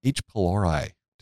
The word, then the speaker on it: H. pylori: And H. pylori. Did I pronounce that correctly? – Yeah, you pronounced that pretty good.